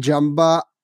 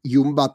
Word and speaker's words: Jumbo: Na druhou stranu, ten tým, viděl jsem i na Twitteru různé mýmy a tak podobně, když srovnáte sílu vlastně Jamba nebo Jumba teda, jak říkal Vojta, že se má, to má správně vyslovovat. – A Jumbo.